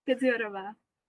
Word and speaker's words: Kedziorova: See if I can get the name right. Nela Kedziorova. Kedziorova. Is that correct? – Kedziorova.